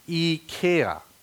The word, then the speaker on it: IKEA: And then, so I kind of Googled that thing, and I found out that it's really supposed, you know, it's a Swedish company, right? It's supposed to be pronounced IKEA, but I thought everybody calls it IKEA, right?